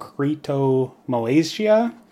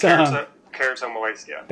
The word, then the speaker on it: keratomalacia: And one of the worst cases reported was a soy formula-fed baby diagnosed with something called keratomalacia. Totally did not pronounce that right, but that's okay. It's keratomalacia. – There you go.